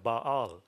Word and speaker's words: Baal: It's actually a two-syllable word, Baal.